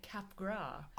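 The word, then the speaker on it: Capgras: It is, because a moron that I am, I've been pronouncing it cap gras syndrome. Right. And it turns out it's by a French guy. So it's definitely cap gras, and therefore anyone who heard me talk about it obviously knew that I was talking nonsense.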